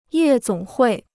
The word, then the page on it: 夜总会: yè zǒng huì